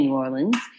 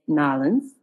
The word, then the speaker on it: New Orleans: As a Texan and a Southerner, we don't actually say New Orleans. It's Nolens.